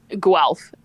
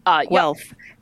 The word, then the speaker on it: Guelph: So with this new snake that you just bought yesterday, will you be going through Guelph? Is that how you say it? – Guelph. – Guelph. – Yeah.